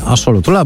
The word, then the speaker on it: axolotl: W Mexico City wszędzie widać wizerunki axolotla, a właściwie axolotla, bo tak się to wymawia, czyli ambystomy lub też salamandry meksykańskiej.